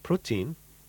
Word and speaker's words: protein: We have protein. Pronunciation may be a little bit different.